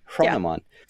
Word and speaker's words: Hronomon: Like you pronounce the H and then you say Hronomon. Hronomon.